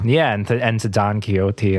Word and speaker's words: Don Quixote: which is the musical version of Don Quixote, which is where the phrase "they might be giants" originates. – Don Quixote. – Yeah, that's the thank you, Dave. – The correct pronunciation.